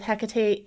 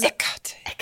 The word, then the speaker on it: Hekate: Because the H is silent. – Like, et cetera. – Et cetera. Hekate. Or Hekate. The H being silent or not, but still putting the emphasis on that middle syllable, Hekate.